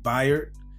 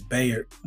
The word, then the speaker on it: Bayard: But I think he pronounced his first name Byard, even though it looks like it says Bayard.